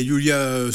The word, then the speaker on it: Yiula: Alors, je prononce Yulia parce que ça s'écrit Y-I-U-L-A, qui la remplace et qui la remplace plutôt bien.